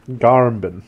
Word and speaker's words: Garmbin: Garmbin. You have to say it like that. – Garmbin. – Like, you started to say Garfield and then you have to think of another word halfway through.